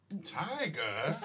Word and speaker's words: Tyga: Kylie banged, is it Tyga? Is that how you say his name? – Yeah. – Tyga? – I don't listen to that shit.